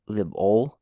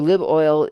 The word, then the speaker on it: liboil: Okay, liboil, O-I-L, oil. Say that as 2 syllables, libol, liboil. So liboil is a library of simple functions optimized for various CPUs.